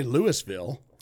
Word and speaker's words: Louisville: You don't say Louisville.